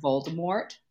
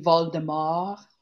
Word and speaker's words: Voldemort: Did you know that also technically his name is French, so it's not actually supposed to be pronounced Voldemort? – I know, but I hate leaving off the T. – It's supposed to be Voldemort.